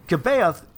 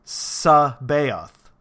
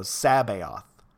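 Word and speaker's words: C'baoth: Kabaoth, incidentally, is pronounced Sabaoth, with the first vowel pronounced like the A in has. So Sabaoth. It's a soft C.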